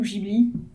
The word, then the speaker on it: Ghibli: Ghibli ou Ghibli, selon la prononciation que vous voulez. Je crois que la vraie c'est Ghibli, mais personne ne l'a dit comme ça, mais c'est pas grave.